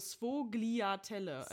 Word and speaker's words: Sfogliatelle: Das schreibt man S-F-O-G-L-I-A-T-E-L-L-E. – S-F-O? – Ja, Svaliatelle. Und dann aber Svoliatelle oder Svaliatelle. Das O spricht man irgendwie in A aus. – S-F. – Also Svogliatelle.